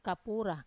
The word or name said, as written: kapura